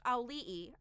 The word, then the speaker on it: Auli'i: Um, it's pronounced Auli'i.